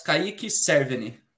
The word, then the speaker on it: Kaique Cerveny: Yes, Kaique Cerveny.